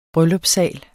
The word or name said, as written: bryllupssal